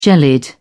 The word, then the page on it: jellied: British English